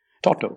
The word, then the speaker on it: Toto: Oh, well, Kyle decided it was Tato. Called Toto, yeah. – Toto.